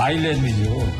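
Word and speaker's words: Island: 제목은 그 아일랜드 아이슬랜드 인더스트림. – 아이슬랜드가 아니죠. – 뭡니까? – 아일랜드죠. – 아일랜드니까? 왜 근데 아이스는 묵음이죠, 묵음. – 그거 언제 묵음이 됐냐, 그거? – 네, 옛날부터 묵음이 됐어요.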